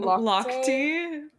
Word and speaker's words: Lochte: Lochte.